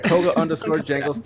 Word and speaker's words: Koga_Jangles: You can find me on here on Twitch and Twitter at Kogas_Jangles. Did I say that right? I feel like I made a mistake. – You did say Kogas instead of Koga. – Find me here at Koga_Jangles.